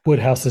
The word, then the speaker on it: Woodhouse: And since my first name is difficult to spell, I'll just spell it, which is L-E-I-G-H-T-O-N. Woodhouse is just how it sounds.